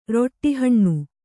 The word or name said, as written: ರೊಟ್ಟಿಹಣ್ಣು